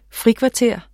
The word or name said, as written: frikvarter